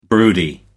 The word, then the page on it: broody: American English